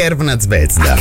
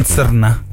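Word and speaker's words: Crvena Zvezda: Kerna Zvezda. – Kerna? Non Zerna.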